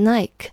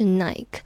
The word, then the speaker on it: Nike: 文章当中列出了一些企业，比如 McDonald's 麦当劳、 Nike 耐克。 Starbucks、星巴克、 Walmart and KFC、沃尔玛和肯德基。其中要注意的是，Nike 这个品牌呢，很多人会念成 Nike，可能是因为中文的音译耐克，让许多人误以为它的英文发音是 Nike，实际上它应该读成 Nike。 Nike 这个词源于希腊语的胜利女神，The Goddess of Victory。